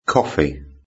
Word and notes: coffee: British English

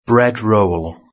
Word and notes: bread roll: British English

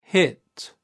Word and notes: hit: British English

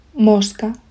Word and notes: mosca: Spain Spanish

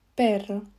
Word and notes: perro: Spain Spanish

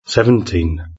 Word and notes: seventeen: British English

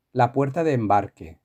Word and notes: la puerta de embarque: Spain Spanish

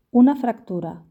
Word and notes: una fractura: Spain Spanish

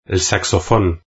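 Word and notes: el saxofon: Spain

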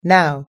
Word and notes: now: American English

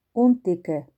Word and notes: un tique: Spain Spanish

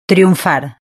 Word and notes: triunfar: Spain Spanish

